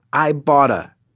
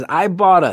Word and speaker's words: Ibotta: Have you heard about Ibotta? That is Ibotta. That's spelled I-B-O-T-T-A.